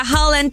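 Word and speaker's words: Haaland: Oigan, pues estamos ya de regreso y vamos a platicar de esta inesperada cena que tendrán Tom Holland con el futbolista Holland también. Sí, se pronuncian igual, aunque se escriban diferente.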